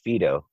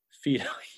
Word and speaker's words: Fido: I think it's pronounced Fido. – Fido. Yeah, that's right.